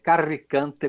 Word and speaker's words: Carricante: Grequeto, que se escreve com CH e dois T's, G-R-E-C-H-E-T-T-O. Carricante com dois R's, eu estou pronunciando como italiano, mas seria Carricante. Carricante, C-A-R-R-I-C-A-N-T-E.